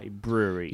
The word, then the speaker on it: Bruery: B-R-U-E-R-Y. Brewery.